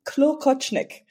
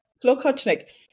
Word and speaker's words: Klokotschnik: Klokotschnik. It's actually Klokotschnik. – Klokotschnik. It's actually Bohemian, and it's supposed to mean bell caster.